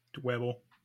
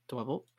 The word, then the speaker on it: Dwebble: Dweeble? Dwebble? – Dwebble. – Dwebble.